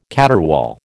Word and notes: caterwaul: American English

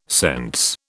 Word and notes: sense: British English

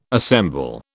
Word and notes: assemble: British English

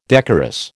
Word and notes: decorous: American English

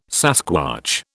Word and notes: sasquatch: British English

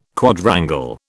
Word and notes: Quadrangle: British English

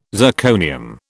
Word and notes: zirconium: British English

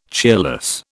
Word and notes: cheerless: British English

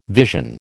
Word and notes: vision: American English

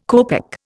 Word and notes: copeck: Indian

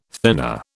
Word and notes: thenar: British English

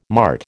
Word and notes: mart: American English

